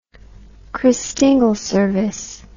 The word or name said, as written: Christingle service